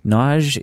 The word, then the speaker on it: Nagy: Nagy is pronounced Nagy. It is spelled N-A-G-Y, like Nagy or Nagy, but it's pronounced Nagy.